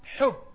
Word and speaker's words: حب: Ha, can you pronounce it? Hub. Ha, حرف من أقصى الحلق. ثم باء، حرف من أخشفتين.